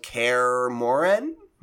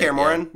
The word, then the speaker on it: Kaer Morhen: Bandai Namco and CD Projekt Red have confirmed that Geralt and a stage called Kaer Morhen is how you say it. – Kaer Morhen, yeah.